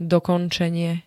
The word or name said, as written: dokončenie